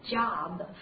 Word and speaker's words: job: 在美音当中发音的时候，也希望大家发的稍微啊夸张一点，下颌骨打开，那么发一下啊，对不对？job。